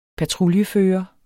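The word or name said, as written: Patruljefører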